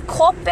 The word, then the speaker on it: kope: And kope is spelled K O P E, and it's pronounced ko pe.